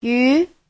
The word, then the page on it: yu: yu2